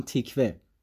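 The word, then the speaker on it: Tykwer: فیلمی که از روی این اثر ساخته شده رو خواهران واچوفسکی فعلی و برادران قبلی و تام تیکور با تلفظ آلمانی تام تیکوه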